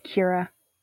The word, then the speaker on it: Kira: I will be your host for today, and today's special guest is Rampian crew member, I always want to say Kiera. – It's pronounced Kira. – Kira. – Like okay. Kira Knightley. – Ooh, like Kira Knightley.